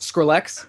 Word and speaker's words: Skrillex: Is it Skrillex or Skrillex? – It's pronounced Skrillex, yes.